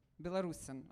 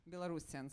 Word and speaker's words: Belarusian: Or am I actually pronouncing, is it Belarusian in English? – Belarusian. – I thought I'd make Belarusian. So, thank you.